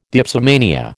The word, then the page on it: dipsomania: American English